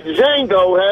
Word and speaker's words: Django: Skip, I even tweeted, what does the Cowboys and the movie Django have in common? – What? – The D is silent.